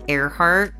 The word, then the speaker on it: Ehrhardt: No, they can find me on my Twitter at Shell Earhart, spelled like Michelle, but with the M-I cut off. And the last name is E-H-R-H-A-R-D-T.